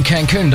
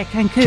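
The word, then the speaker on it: Cancún: He's off on a holiday to Cancun or Cansun. I'm not sure how to pronounce that. I didn't have time to look it up. – You mean Cancun, don't you? – Maybe so. Yeah, Cancun.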